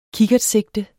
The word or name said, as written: kikkertsigte